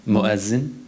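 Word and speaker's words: muezzin: So you are an imam, and what we would understand as a cantor, and the correct word is... – Muazzin. – Muazzin. – Yeah. – I'm still not saying it right.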